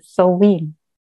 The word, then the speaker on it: Samhain: Es ist eigentlich auch egal, wie man es letztlich ausspricht, aber die korrekte Aussprache wäre Samhain.